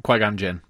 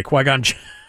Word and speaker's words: Qui-Gon Jinn: How do you pronounce the Star Wars? – Yeah. – Qui-Gon Jinn. – Okay, Qui-Gon Jinn.